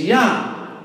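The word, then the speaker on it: Yah (יה): His name is Yah. The J is silent. It's a Y sound.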